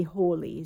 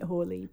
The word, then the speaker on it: Halley: I believe we've been pronouncing it wrong for years. It's actually Hawley is the way that it's supposed to be pronounced, but it feels weird. – Whoops. – After 47 years of calling it Halley. I can't get my head around calling it Hawley.